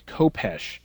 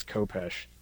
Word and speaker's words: Kopecz: One of the ones that probably people will have the most trouble with, there's a Dark Lord or a Sith Lord named, his name's Kopesh. Is how Drew pronounced it, but his is the name that's spelled K-O-P-E-C-Z. So just in case people are wondering how to pronounce that, it is Kopesh.